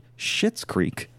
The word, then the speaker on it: Schitt's Creek: Also, it has recently come to my attention that I mispronounced the name of that show that I mentioned right before the break. According to our wonderful engineer and producer, Will Scoville, it is called Schitt's Creek.